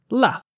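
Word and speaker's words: 辣: And again, the fourth tone, the way the word spicy is pronounced is la. – Shit. La.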